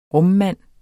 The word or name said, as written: RUMMANDEN